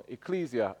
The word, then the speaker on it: ecclesia: Now, from the outset, I will admit and confess that the word ecclesia is not pronounced necessarily or technically correctly. So for those who know how it's pronounced, it seems they're always trying to correct the way that we say the name. What church are you from? Ecclesia. Oh, ecclesia. No, ecclesia. That's how we say it in South London.